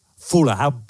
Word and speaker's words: Fulham: Fulham, bien, Fulham.